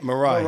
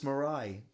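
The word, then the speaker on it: Mirai: Mariah. – Mariah. – There's no A. – There's no at the end. Just Mariah. – So you just stop. – Yeah, Mariah Nagatsu. – Mariah.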